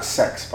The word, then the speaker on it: CeX: And, my mum calls that sex, by the way. – That's how you're supposed to pronounce it.